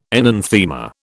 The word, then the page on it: enanthema: British English